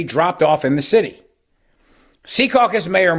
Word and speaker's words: Secaucus: And the mayor of Secaucus, New Jersey, Secaucus, as the locals say it up there, also not happy.